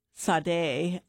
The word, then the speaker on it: Sade: We just heard from Diana Ross, Upside Down, Etta James, At Last, and Sade. I just looked that pronunciation up, which is a Nigerian pronunciation.